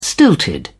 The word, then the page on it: stilted: British English